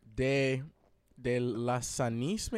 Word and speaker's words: De Lasanis: De Lasanis, maybe. – There you go. – That's how it's said. – Yeah. That's how it's said. – There it is, the definitive pronunciation.